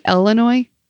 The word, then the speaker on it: Illinois: My only question, did you just say Illinois? – I don't know.